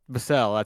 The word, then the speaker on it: Vassell: What is it, Devin Vassell? – Devin Vassell? – Vassell, that's how you pronounce it, yes.